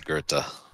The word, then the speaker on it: Goethe: I believe the correct pronunciation is Goethe.